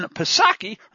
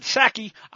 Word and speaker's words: Psaki: Psaki. Remember, the P is silent, like in Resident,